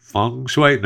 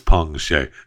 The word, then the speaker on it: Peng Shuai: I think it's Peng Shuai. Is it Feng Shui? No, it's Peng Shuai. P-E-N-G-S-H-U-A-I, tennis star from China.